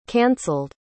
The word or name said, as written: canceled